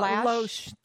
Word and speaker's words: Loesch: Dana Lesh? – Well, Loesch. To me, it's Loesch. I don't know. I don't care if I mispronounce her name.